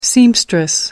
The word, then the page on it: seamstress: American English